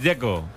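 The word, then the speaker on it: Džeko: Porque DZ, ¿cómo se pronuncia? – Dzeko. Bruno dice que es Dzeko. – Como tirando a J, Dzeko o algo así.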